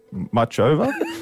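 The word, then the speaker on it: Muchová: Ash Barty is on court this morning against 25th seed Caroline Muchova. Is that how you say it? – Yeah, that'll do.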